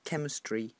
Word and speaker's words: chemistry: The problem on the board on the right was just the pronunciation of words like chemistry, which is a k like key, and quantitative, question.